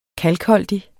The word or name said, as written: kalkholdig